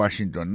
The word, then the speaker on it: Washington: Si on a mis accent sur accent encore, on va voir que le mot Washington, accent tombé sur le premier syllabe là.